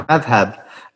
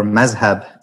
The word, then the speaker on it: madhab: So, you know, I mean, that term madhab in Arabic, or mazhab in Persian, Turkish, Urdu pronunciation.